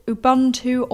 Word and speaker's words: Ubuntu: But funny enough, we actually had the same suggestion from Brian Aykroyd, who recommended Ubuntu or Mint.